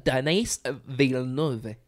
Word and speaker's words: Denis Villeneuve: Denis Villeneuve, if I'm saying that correctly. – It's Denis Villeneuve.